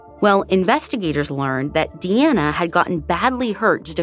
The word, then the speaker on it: Deana: So for consistency's sake, we're pronouncing her name Deana, which is how detectives pronounce it. Anyways, even before the results of the test came back, investigators were pretty convinced that Deana was indeed their Jane Doe.